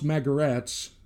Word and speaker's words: Magarac: But anyway, we have the legendary Joe Magaratz, spelled Magarac, pronounced Magaratz, because it came from the Croatian word for donkey or jackass.